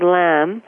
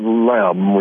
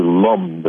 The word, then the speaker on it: lam: And well, it's really not lam. We have to pronounce it with a very broad sound here that's in the vowel, like this. Lam, lam. Almost closer to lum than lam.